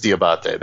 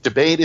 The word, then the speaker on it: D'Abate: The Italian pronunciation of my name is Diabate. That's how it's pronounced in the old country. That's how my family over there would pronounce it. So technically, that's correct. Here in the States, it was Americanized years ago by my family that lived here. Really, either way is acceptable.